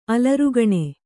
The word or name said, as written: ಅಲರ್ಗಣೆ